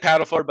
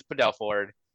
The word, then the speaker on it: Padelford: That's yeah, it's not it looks like Padelford, but it is Padelford.